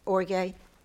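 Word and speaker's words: orgē: Now, let me talk to you just a minute about, Kathy, you want to teach us about orge? About the wrath of God. – I'm not going to look up. – Okay. All right. God's wrath. Greek word O-R-G-E. Long E.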